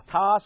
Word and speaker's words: Ptah: Bulls were also thought to be the very embodiment of Ptah and Ra. Ptah spelled with a P, a Ptah.